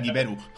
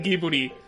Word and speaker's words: Ghibli: Y, bueno, sí, pero hay grandes estudios se pronuncia Giberu. Así que. – Sí, es que. Giburi.